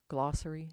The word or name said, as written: glossary